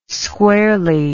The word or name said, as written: squarely